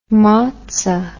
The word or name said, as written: motsa